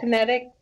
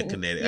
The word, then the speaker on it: kinetic: Kernic? I can't pronounce it. Kernic energy? – Oh, kinetic. – Yeah, kinetic. Yeah.